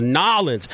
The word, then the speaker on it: New Orleans: It's New Orleans, but people down there told me it's pronounced Nollins.